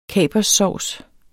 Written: kaperssovs

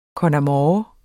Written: con amore

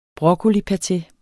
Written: broccolipaté

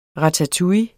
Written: ratatouille

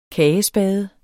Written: kagespade

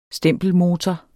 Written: stempelmotor

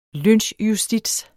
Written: lynchjustits

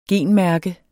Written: genmærke

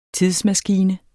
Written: tidsmaskine

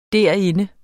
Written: derinde

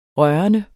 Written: rørende

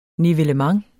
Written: nivellement

